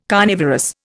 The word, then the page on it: carnivorous: Indian